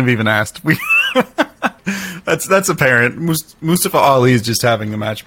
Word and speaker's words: Mustafa Ali: The Intercontinental Championship, Gunther and Mustafa Ali.